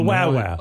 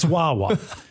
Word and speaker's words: Wawa: To call it a Wawa, but it's not Wawa, it's Wawa.